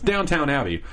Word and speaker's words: Downton: It's Downton Abbey.